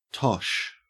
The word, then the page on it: tosh: Australian English